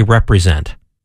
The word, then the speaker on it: represent: Hey, and one of the words she often uses, but puts the emphasis on a different syllable, is what we would say represent. No, she says re-present, because that's what we're supposed to do as Jesus's ambassadors in the world.